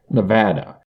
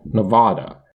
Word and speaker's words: Nevada: Nevadans pronounce the second syllable with an A as in strap, Nevada, while some from outside of the state can pronounce it with the A as in palm, Nevada. While the latter pronunciation is closer to the Spanish pronunciation, it is not a pronunciation used by native Nevadans.